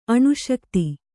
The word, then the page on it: ಅನಾಸಕ್ತೆ: anāsakte